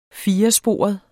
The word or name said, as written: firesporet